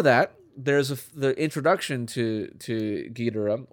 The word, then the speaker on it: Ghidorah: Yeah, I think the first one you said is actually like the Japanese pronunciation of Ghidorah. – Yeah. – Like with no emphasis. I try to pronounce it that way, but I, you know. – No emphasis on the syllables.